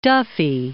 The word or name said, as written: Duffy